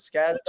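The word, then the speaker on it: Skattebo: How do you say it? What is it? Scatabo. Scatabo. – Scatabo. – Scatabo, thank you.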